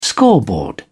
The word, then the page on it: scoreboard: British English